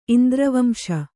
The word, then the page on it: ಇಂದ್ರವಂಶ: kannada